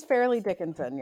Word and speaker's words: Fairleigh Dickinson: It is Fairleigh Dickinson, yes. – So it's like it's not mostly Dickinson, it's fairly Dickinson. – Yes, correct.